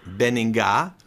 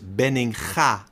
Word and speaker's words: Benninga: Like you pronounce my name, which is not Benninga, but the real Dutch pronunciation is Benninga, the ugly G from in Dutch.